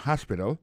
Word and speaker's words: hospital: L'hôpital c'est comme ça on dit ça en anglais, hospital. Alors, accent tombe sur le premier syllabe, il y a trois syllabes, puis il a accès à visite hospital. Ensuite, elle a écrit H-O-S-P-I-T-A-L, hospital, l'hôpital.